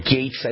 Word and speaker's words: Gaetz: So I mentioned as we were going to break that Representative Matt Gaetz or Gates, I never know how to pronounce his last name. I think it's pronounced Gates.